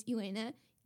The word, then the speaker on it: Elana: So my name is Elana, E-L-A-N-A dot Melzer, and that's spelled M as in Mary, E-L, Z as in zebra, E-R.